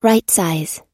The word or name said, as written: rightsize